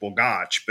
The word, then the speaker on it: Bogacs: people always get really uptight about the pronunciation, and I always tell them that, so my family's Austrian, but we anglicize it. So my writer name is Christoph Borgarch, but just when I'm at home because I'm lazy, I'm Christoph Borgers. So even my family doesn't pronounce it right.